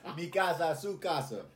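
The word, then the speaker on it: mi casa su casa: Welcome. Sukasa Mika. Oh, dang, I said that wrong. Mikasa Sukasa.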